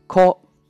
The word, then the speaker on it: こ: 第五个假名 “ko”。抠，抠的发音呢是比较简单的，但是呢要注意不能读成抠门的抠。关键点就在于发抠的时候，嘴巴张开以后呢就不要闭上，直到发完这个音为止。